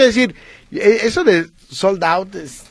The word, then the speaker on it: sold out: Oh, según escuchaba, fue un éxito porque el 80% de los artistas que se presentaron tuvieron soldado. – ¿De verdad? – ¿Cómo soldado? ¿No se dice así, soldado? – Sold out.